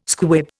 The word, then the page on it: squib: Indian